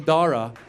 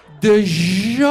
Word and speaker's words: Dara: It's the most entertaining shit because his teachers don't know what to do with the silent H in Dara. And they don't want to mess it up. They don't want to offend us. So we walk in. I see his white teachers. They're sweating bullets. And they start saying his name, hoping that we'll help them out. They're like, you know, your son, Deha, Deja, Deha. I go, there's no Kha. Where'd you see the Kha? The H is silent.